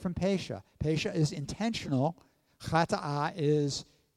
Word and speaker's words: pesha: And in Hebrew, it's the word pesha. Pesha, you'd spell it P-E-S-H-A if you're writing it out in English. So that's pesha.